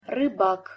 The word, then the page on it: rybak: Russian